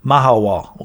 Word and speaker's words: Mahahual: That got us out of the port down to the nearby city of Mahahual, pronounced Mahawal. Okay, Mahawal.